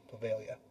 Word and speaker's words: Poveglia: Bovalia, Italy. Bovalia Island. If you guys want to search it up, it's spelled P-O-V-E-G-L-I-A Island, Bovalia.